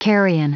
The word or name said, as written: carrion